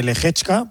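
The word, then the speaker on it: Lehečka: A las 11 de la mañana, hora española, la final de Tokio ante Lehechka.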